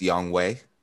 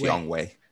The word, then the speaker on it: Younghoe: So I do want to say that it's Youngway. It's pronounced Youngway.